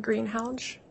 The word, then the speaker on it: Greenhalgh: Alyssa Greenhalch, A-L-Y-S-S-A, and the last name is G-R-E-E-N-H-A-L-G-H.